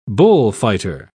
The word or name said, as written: bullfighter